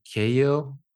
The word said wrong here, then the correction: Keogh: I think if I'm pronouncing her name right. She's just amazing. She's just a marketer sharing her. – Is that K-E-O-G-H, Kia? – Correct, Kia. Yeah, that's correct. Right.